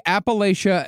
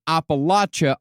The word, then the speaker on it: Appalachia: By the way, Appalachia and Appalachia are both accepted pronunciations. Usually, if you're more northern, you'll say Appalachia.